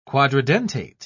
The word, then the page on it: quadridentate: British English